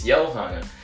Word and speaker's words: Johanna: So yeah, some people might say Ohana. Ohana means family. No, it's Johanna.